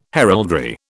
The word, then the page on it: heraldry: British English